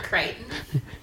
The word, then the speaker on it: Crichton: Is it Crichton? – Crichton.